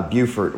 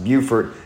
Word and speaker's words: Beaufort: Say, Warner students don't know what's happening at University of South Carolina, Beaufort, which I just learned it's pronounced Beaufort instead of Beaufort, so I've been saying that wrong for a little while.